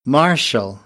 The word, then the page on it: marshal: American English; ɑːr